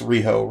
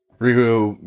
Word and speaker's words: Riho: Also, Kenny Omega had said recently, he suggested that we look into, and it's pronounced Riho, right? – Riho Mizunami.